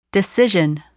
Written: decision

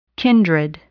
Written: kindred

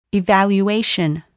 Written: evaluation

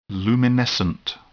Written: luminescent

